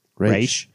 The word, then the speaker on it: Ra's: Measures are taken to deprogram Sophia and understand the extents of her abilities, while Raz Ghul, Raz? – Raish.